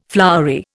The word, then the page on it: flowery: Indian